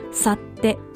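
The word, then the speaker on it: 幸手: 埼玉県で複数の方がおすすめしてくださったのが、去って、幸せの手と書いて、去ってと読むんですけれども、そちらの権現堂包みというところです。こちらも去って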